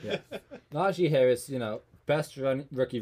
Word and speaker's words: Najee: So at 32, the new running back, Najee Harris. I knew I was pronouncing that wrong. Spanish. – The J's are actually J's.